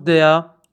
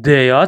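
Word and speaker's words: der: دوستان، دوستان خیلی مهمه بدونید حرف در در زبان آلمانی دیا تلفظ میشه، دیا. خیلی مهمه که خود آلمانی، خب اکثرا دوستان در تلفظ میکنن، ولی باید بدونید که خود آلمانی ها دیا تلفظ میکنن.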